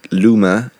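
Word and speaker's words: Leuven: And then I sadly left Bruges and went to Lumen, I think it's called. It's spelled Lumen, but it's Lumen.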